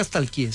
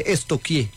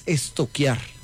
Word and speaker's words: stalkear: Porque no se dice nunca stalkeé, se dice estoquié. – ¿Estoquié? Sí. – Pregúntele a la profe, verá. A mí nada más. – Estamos estoqueando. – Sí, estoqueando. Yo, pero se escribe stalkear. Es estoquear.